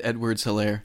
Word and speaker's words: Edwards-Helaire: Clyde Edwards-Helaire.